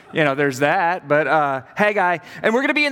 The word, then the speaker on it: Haggai: And then all of a sudden, whiplash, we're going to be talking about the Old Testament book of Haggai, however you pronounce that. I say Haggai usually, and then I've been corrected a couple of times, and here we are, Haggai.